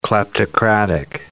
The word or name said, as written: kleptocratic